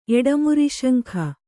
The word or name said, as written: ಎಡಮುರಿಶಂಖ